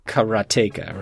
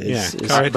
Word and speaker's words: Karateka: They spell it out in the game. They tell you there are the different ways, but I guess it's Jordan himself pronounces it Karateka, right? – Karateka. – But I love that this has existed for so long that it doesn't matter as much how the creator pronounces it, because the guy remastering it pronounces it differently.